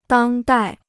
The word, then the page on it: 当代: dāng dài